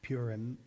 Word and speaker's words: Purim: I just heard today that at 6 p.m., the Jewish festival known as Purim, or Purim, you might pronounce it, it's the Jewish celebration of God's saving intervention that we read about in the book of Esther.